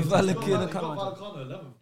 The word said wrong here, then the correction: Vallecano: You don't even know how to say his name. Vallecano.